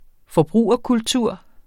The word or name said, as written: forbrugerkultur